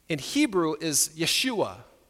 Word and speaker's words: Yeshua: His name in Hebrew is pronounced Yeshua, and it means God saves.